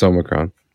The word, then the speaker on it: Omicron: Yeah, it's Omicron. Like Megatron. – Like in Futurama, Omicron Percy. – Is it O-M-I Omi? Like O-N-I is Oni? – O-M-I-C-R-O-N.